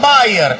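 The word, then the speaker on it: Beier: Este se pronuncia igual, pero se escribe distinto al de la aspirina. Maximilian Bayer, este B-E-I-E-E,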